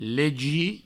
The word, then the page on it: legir: provençal; legi